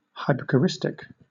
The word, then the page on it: hypocoristic: British English